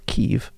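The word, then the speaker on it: Kyiv: or Kiev, as they pronounce it, and he made two very interesting remarks to me.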